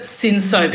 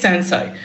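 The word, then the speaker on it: Cinsault: Well, sometimes here we pronounce it Sinso, because that's how people, you know. – That's how it's spelled. – We're a bit Australian. It is Sanso. Technically it's Sanso, but sometimes we call it both, just so you can identify to a customer so they can connect to what we're all talking about.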